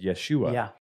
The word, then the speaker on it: Yeshua: And if you don't know the little play on words here, the Hebrew word for Joshua is Yeshua, which is also the way Jesus' name would have been pronounced in Hebrew.